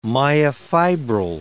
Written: myofibril

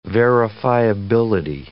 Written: verifiability

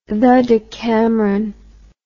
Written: decameron the

